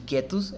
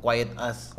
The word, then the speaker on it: quietus: El quietus, ellos muchos lo pronuncian como quiet as.